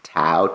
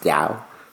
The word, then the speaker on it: Tao: For y'all don't know, like you might have seen the word Tao, T-A-O, but it's actually pronounced Gao.